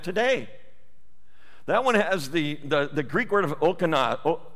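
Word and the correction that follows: Diakonos (διάκονος): Okanos, am I saying that right? Yeah. I'm saying something like that. Diakonos, that's the word.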